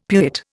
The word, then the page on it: pewit: Indian